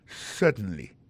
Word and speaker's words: certainly: certainly alors en parlant de accent regardez bien pour un mot certainly accent tombé sur premier syllabe là yes certainly t'aimerais prendre pour encore yes certainly comprenez accent tombé sous premier syllabe là.